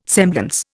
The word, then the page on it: semblance: Indian